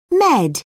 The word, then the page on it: med: British English